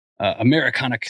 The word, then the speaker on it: Americonic: Americonic Single Malts, rhymes with Hariconic.